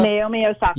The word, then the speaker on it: Naomi Osaka: Is it Naomi Osaka? – Naomi Osaka.